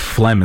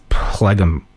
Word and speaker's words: phlegm: Imagine my shock when I was reading Harry Potter at the ripe age of 8 years old or whatever, and then finding out that phlegm is spelled like that. – Yeah. – It was a chapter title in that book, and I needed to know how to say it. Because I'm like, what is plegum? – Yeah.